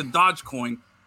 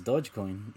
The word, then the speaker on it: Dogecoin: I put more stock into Dogecoin than I do into any single soccer rumor or transfer rumor when it comes to soccer. – Look, you don't even pronounce it correctly, bro. It's Dogecoin.